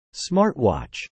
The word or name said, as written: Smartwatch